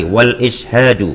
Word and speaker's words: wal-ishadu: Nah, ini bacanya gitu ya. Wustahabu iradatu al-islahi wal-ishadu wal-i'lamu.